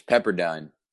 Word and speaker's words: Pepperdine: that one. It's Pepperdine.